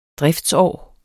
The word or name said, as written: driftsår